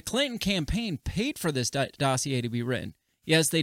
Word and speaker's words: dossier: However, the dossier, how do you pronounce that? – Dossier. – Why is there an R in it? – It's freaking French people. – It's French.